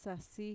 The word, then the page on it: sasi: Mixtec